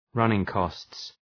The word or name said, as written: running costs